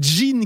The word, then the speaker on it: DGIN: Et nous continuons avec Jean qui s'écrit D-G-I-N, hein, qui rappelle la sonorité du célèbre spirituel.